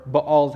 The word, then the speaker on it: Baal: Your dad has this altar to this false god named Baal, or Baal is how it's properly pronounced, but I've heard so many people say Baal that I'm going to use the two interchangeably, so sorry.